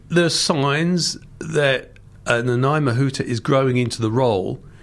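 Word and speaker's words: Nanaia Mahuta: Well, you were at the conference, and probably before that, you met our relatively new foreign minister, Naomi Mato. I'm probably mispronouncing her name. – Nanai Mahuta. – Nanai Mahuta.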